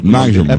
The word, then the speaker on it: Nagelsmann: Não, só de ontem não, só com relação ao técnico da Alemanha, o Nagelsmann, essa Nagelsmann. Pronúncia do Nagelsmann. – É perfeito.